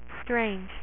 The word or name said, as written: Doctor Strange